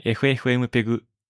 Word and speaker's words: FFmpeg: はい、 FFmpeg と読みます。 FFmpeg と書いて。 FFmpeg Library というのがあります。で、下印式で読んでみましょう。 – リンク FFmpeg Import スラッシュ Export Library。 – はい、というリンクがありますので、これでエンターを押します。 – インストーリング FFmpeg 縦線オーダーシティサポート。